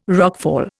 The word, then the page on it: rockfall: Indian